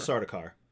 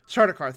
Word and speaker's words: Sardaukar: I can never pronounce their name, the soldiers of the emperor, the Sardaukar. – Sardaukar, thank you.